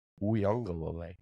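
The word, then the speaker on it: Uiagalelei: Let me say his name quick. DJ Uyunglele. – Ooh, nice. Wow, nailed it!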